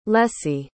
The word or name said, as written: lessie